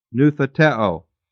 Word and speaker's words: noutheteo: Similarly, the word noutheteo, N-O-U-T-H-E-T-E, long O, noutheteo, you know, nouthetic counseling, means to warn, admonish, confront.